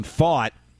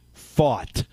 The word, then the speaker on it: Pfaadt: Brandon Faught, and by the way, that is how you pronounce it, P-F-A-A-D-T, Brandon Faught on the mound tonight for Arizona, and of course we'll have it for you here on 106.1 ESPN.